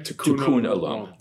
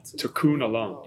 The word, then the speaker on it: Tikkun olam: Tikkun olam. – Tikkun olam.